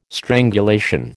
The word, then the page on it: strangulation: American English